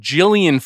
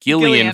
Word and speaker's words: Gillian: He says I hired Gillian Flynn to write the whole thing instead of Gillian Flynn, which is how her first name is pronounced.